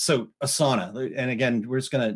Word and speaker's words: Asana: I've talked about it before on some Small Business Saturdays, so we won't get too crazy here, but it's called Asana, and it's A-S-A-N-A, and it is pronounced Asana. We actually took a webinar training thing, and so one of the people that worked there called it Asana, and so we're like, okay, good, we're pronouncing it right.